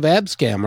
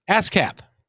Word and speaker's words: ASCAP: And even though you're part of Abscam, are you not? Did I say it correctly? ASCAP.